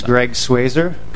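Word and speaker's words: Greg Schweser: My name is Greg Swazer.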